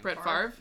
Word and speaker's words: Favre: Brett Favre.